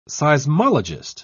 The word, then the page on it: seismologist: British English